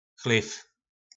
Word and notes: Chlyv: Po našimu

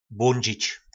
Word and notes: Blondzic: Po našimu

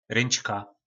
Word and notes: Ryncka: Po našimu

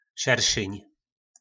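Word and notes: Sersyn: nářečí Po našimu

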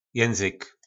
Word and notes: Jynzyk: nářečí Po našimu

